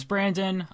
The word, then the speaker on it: Branden: And uh, just so you guys know who I am, my name is Branden.